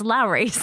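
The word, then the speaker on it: Lawry's: Lowry's, is that how you pronounce it? – Yeah, it's Lowry's.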